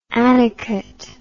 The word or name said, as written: annicut